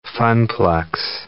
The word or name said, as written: funplex